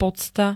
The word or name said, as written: pocta